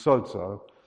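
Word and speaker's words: sozo (σῴζω): And the word there, saved, is actually pronounced sozo.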